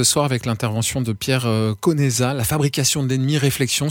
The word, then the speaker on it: Conesa: J'ai entendu, c'est Pierre Conesa à l'italienne.